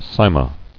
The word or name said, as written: Sima